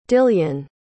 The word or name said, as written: dillion